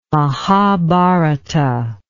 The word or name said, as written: Mahabharata